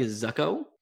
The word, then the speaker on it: Zuko: Moving on to our buddy Zuko.